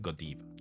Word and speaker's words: Godiva: But in Belgium, if you want to be very precise, in Belgium they pronounce it Godiva.